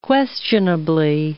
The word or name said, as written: questionably